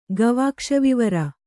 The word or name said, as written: ಗವಾಕ್ಷವಿವರ